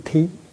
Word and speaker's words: thí: Chúng thí chữ thí này có khi đọc là chữ thi. Thi có nghĩa là làm, như là thi hành. Như thí có nghĩa là hiến tặng. Ở đây mình đọc là chữ thí.